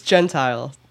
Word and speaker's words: Gentile: Thanks for saying my name correctly. – There is curiosity about that, isn't there? – Yeah, it's Gentile.